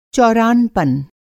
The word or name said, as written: चौरानपन